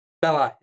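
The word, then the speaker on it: casus belli: Cassius Belli. – Right. – The propaganda you feed within. Belli.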